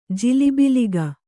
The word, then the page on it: ಜಿಲಿಬಿಲಿಗ: jilibiliga